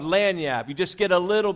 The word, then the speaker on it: lagniappe: Now, it's actually spelled L-A-G-N-I-A-P-P-E, but it's pronounced lagniap.